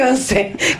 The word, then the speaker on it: Once: Once, se escribe Once, como en inglés Once. – Como Once Upon a Time.